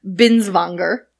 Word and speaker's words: Binswanger: So it's Dr. Ludwig Binswanger.